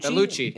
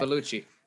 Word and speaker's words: Bellucci: They also go see Monica Bellucci. I can't say her last name. Bellucci? – Bellucci. – Am I saying it right? – Yes, okay. Bellucci.